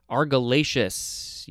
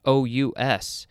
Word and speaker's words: argillaceous: All right, next we have Argillaceous. Yeah, that's how it's pronounced. Argillaceous.